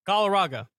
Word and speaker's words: Galarraga: Galarraga.